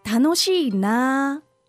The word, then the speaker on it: 楽しいな: We do not say 楽しいな. – No, 楽しいな. Long. – A long one, right?